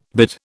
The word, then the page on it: bid: British English